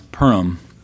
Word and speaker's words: Purim: of Purim or Purim, but we pronounce Purim.